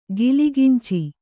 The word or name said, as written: ಕಲಗಂಚು